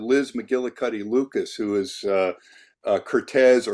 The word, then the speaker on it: Curtiz: So everyone in Hollywood, including his best friends and his closest co-workers, called him Curtiz. So I call him Curtiz, but how he pronounced his name was Cortez.